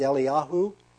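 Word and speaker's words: Eliyahu (אליהו): or the Hebrew way of pronouncing them is Eliyahu and Elisha.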